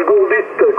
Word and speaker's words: Gorlit: Et le petit village s'appelle Gorlit, comme ça se prononce, Golf, Oscar, Uniforme, Radio, Bravo, Italie et Tango.